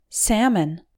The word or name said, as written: salmon